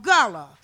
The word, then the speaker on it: Gullah: The language today, it's called gullah. It's spelled G-U-L-L-A-H.